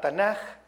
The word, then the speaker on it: Tanaj: Y luego viene la K, que se pronunciaría J, Tanaj,